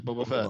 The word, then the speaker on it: Boba Fett: It's Boba, right? Or Boba Fett? – Boba Fett. Yeah, Boba Fett. Oh, okay. I always pronounce Bubba for some reason.